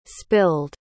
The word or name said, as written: spilled